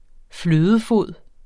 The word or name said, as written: flødefod